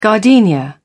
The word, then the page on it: gardenia: British English